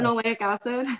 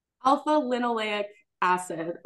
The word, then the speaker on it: alpha-linoleic acid: I take D3 and alpha How do you say Linoleic acid. – There you go. I take that one as well. Say that again for our listeners. – Alpha-linoleic acid or ALA.